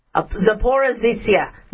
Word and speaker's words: Zaporizhzhia: So a fire at the Zaporizhzhia, okay, let me pronounce this right, Zaporizhzhia nuclear power plant broke out after Russian and Ukrainian forces broke out.